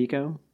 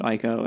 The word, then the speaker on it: Ico: So my next game is Eco, which is uh, do you pronounce it Eco? – I do. – I know some people pronounce it Ico, and I think those people are incorrect.